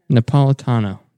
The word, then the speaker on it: Napolitano: Napatillo? Is that how you pronounce it? – Andrew Napolitano. – Napolitano. There we go.